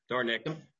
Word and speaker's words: Dornink: Senator Dornink. Sorry, I know I just mispronounced that. Dornink? – Dornick. – Dornick. My apologies.